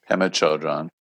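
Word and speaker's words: Pema Chödrön: Pema Chodron.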